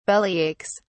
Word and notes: bellyaches: British English

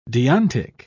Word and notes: deontic: British English

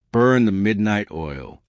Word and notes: burn the midnight oil: American English

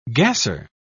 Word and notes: gasser: British English

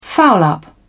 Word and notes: foul-up: British English

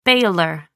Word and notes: bailor: American English

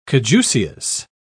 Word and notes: caduceus: British English

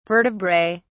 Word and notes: vertebrae: British English

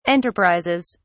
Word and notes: enterprises: British English

